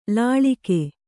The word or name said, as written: ಲಳಗಿ